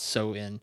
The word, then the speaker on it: Samhain: It's Samhain, but it's pronounced Samhain.